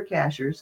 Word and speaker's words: Cashiers: If you go to my website, Sky Ships Over Cashers, Cashers is spelled like a cashier at the grocery store.